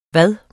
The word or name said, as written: hvad